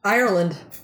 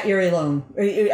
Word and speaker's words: Irelone: I-R-E-L-O-N-E. – Ireland? – Ireland. – It is. – Yeah. – When you say it out loud, it's Ireland. So then I was like, okay. – But I would pronounce that Irilone.